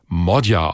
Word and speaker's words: Magyar: Der schreibt sich zwar Péter Magyar, also mit G und Y im Nachnamen, spricht sich aber Magyar aus.